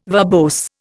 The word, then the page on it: verbose: Indian